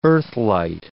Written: earthlight

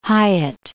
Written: Hyatt

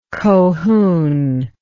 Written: cohune

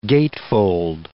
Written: gatefold

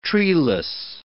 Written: treeless

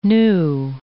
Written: new

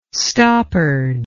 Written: stoppard

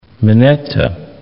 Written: Mineta

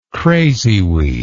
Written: crazyweed

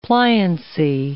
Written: pliancy

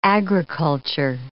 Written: agriculture

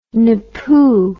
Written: napoo

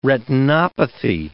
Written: retinopathy

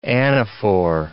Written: anaphor

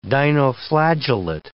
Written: dinoflagellate

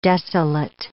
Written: desolate

